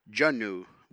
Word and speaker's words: Junu: Junu, which is how they pronounce Mountain Goat here in Klokwan.